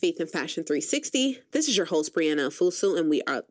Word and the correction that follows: Ofosu: Did I just mispronounce my name? Lord, Ofosu. Say it with me, Ofosu.